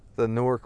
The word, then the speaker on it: Newark: Newark is spelled N-E-W-A-R-K, like New Ark. And you can say it properly, like Newark. Newark, two syllables, but if you're from up there, you just call it Newark. N-O-R-K, Newark, right?